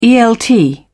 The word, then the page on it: elt: British English